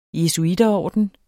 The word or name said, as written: jesuiterorden